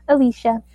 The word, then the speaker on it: Alesha: Am I saying that right? – Alicia. – It's Alicia. Okay. I'm sorry. Alicia Escobar. – And, you know, I'm used to the Alicia because, you know, my in-laws are Hispanic.